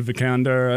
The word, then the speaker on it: Vikander: I was rooting for that movie because I really liked the lead, Alicia Vikander. I don't know how to say her last name. But no, I think that's right.